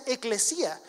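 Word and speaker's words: eclesía (ἐκκλησία): es una palabra griega que se pronuncia eclesía.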